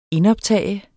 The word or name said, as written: indoptage